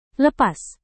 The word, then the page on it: lepas: indonesian